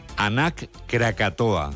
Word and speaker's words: Anak Krakatoa: Nos vamos a Indonesia, donde la erupción del volcán, vamos a ver si lo decimos bien, Anak Krakatoa. – ¿Lo he dicho bien? Sí, parece euskera, pero es indonesio.